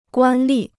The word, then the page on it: 官吏: guān lì